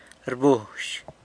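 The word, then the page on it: rbuzh: San Lucas Quiaviní Zapotec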